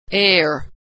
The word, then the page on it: air: American English